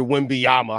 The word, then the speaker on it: Wembanyama: It's like Duncan and Victor Wembanyama. How you say his name? – You got it perfectly.